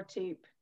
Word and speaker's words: Toop: Currently, you can find me on Facebook and LinkedIn, which is Laura Toop, Bridging the Gap, I think is how it comes up. But my surname is so unique. And it's spelled T-O-O-P for Peter.